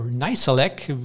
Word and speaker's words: Nizialek: Gar nicht gut, überhaupt nicht gut äh, lief es bei Cameron Nizialek, oder Nizialek, wie man so schön im Englischen ausgesprochen wird.